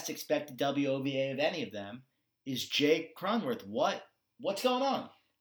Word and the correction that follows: Cronenworth: Jake Cronenworth is...